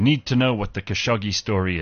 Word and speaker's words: Khashoggi: Yeah, it says on YouTube it's Khashoggi.